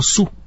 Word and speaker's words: Sue: Es el de una tiranosauria que fue bautizada como Sue. Se escribe S-U-E, el nombre en inglés. Y se pronuncia de la misma forma en la que se pronuncia el término demandar, su.